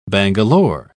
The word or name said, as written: bangalore